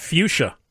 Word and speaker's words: fuchsia: How about fuchsia? F-U-C-H-S-I-A. Fuchsia. There is no... there's no second S there. It's so weird, the spelling on this one, because it's spelled F-U-C-H-S-I-A.